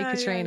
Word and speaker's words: Katriona: But like, it's very easy to say Katriona.